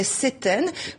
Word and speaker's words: septaine: En moyen français, on prononçait pas le P, on disait septaine, comme sept, le chiffre, on prononce pas le P dans sept,